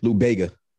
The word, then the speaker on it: Lou Bega: Lou Bega, something. How you say his name? – Lou Bega.